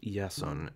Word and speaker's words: Jason: Many Jews who accepted Hellenization usually took on a Hellenized form of their Hebrew name, hence why Yeshua became Jason, pronounced Yason in Greek.